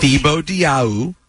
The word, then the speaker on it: Thibodeau: Well, I mean, they're both, you know, Thibodeau, whatever the case may be. – Yeah, even the Nets coach is a star. Thibodeau's a great coach. He's not a star. – Tom star coach. – Thibodeau.